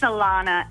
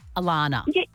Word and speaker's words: Alana: It's Alana. And I get double N-A-H. I get every letter of the alphabet put at the end of it, but it's just A-L-A-N-A.